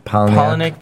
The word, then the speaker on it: Palahniuk: It's pronounced Palahniuk, but it's spelled super Canadian.